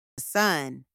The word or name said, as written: son